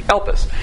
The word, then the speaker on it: elpis: And then you have confidence, because the word hope in the Greek is elpis, E-L-P-I-E-L. Let me get up on the board here and spell it. E-P-I-L-I-S. E-P-I-L-I-S in the Greek, elpis.